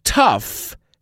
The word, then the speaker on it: tough: La palabra tough sí que es tough de pronunciar. Decimos tough.